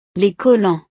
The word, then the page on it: les collants: frances